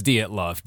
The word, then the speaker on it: Dyatlov: It's Dyatlov. Dyatlov.